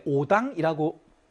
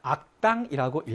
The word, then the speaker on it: 악당: 나쁜 사람들의 무리를 일컬을 때 오당이라고 읽는 것이 아니라 악당이라고 읽어야 되겠죠. 나쁜 것, 좋은 것과 나쁜 것, 선한 것과 악한 것, 일 때는 선오라고 읽는 것이 아니라 선악이라고 읽어야 되겠죠.